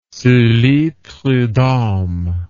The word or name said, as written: sully prudhomme